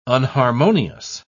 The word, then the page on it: unharmonious: British English